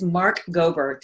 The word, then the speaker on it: Mark Gober: Our guest today is Mark Gober.